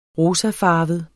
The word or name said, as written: rosafarvet